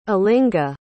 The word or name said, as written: alinga